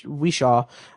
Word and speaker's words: Whishaw: Ben Whishaw, Q from, right.